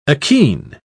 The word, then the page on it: achene: British English